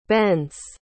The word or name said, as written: bence